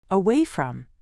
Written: away from

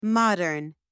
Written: modern

